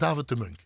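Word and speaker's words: David De Muynck: En je schrijft David de Munk D-E-M-U-Y-N-C-K. – Ja. – En je zegt David de Munk. – Ja, zoals eh, de meeste Belgische namen, zo ingewikkeld mogelijk schrijven en zo makkelijk mogelijk uitspreken.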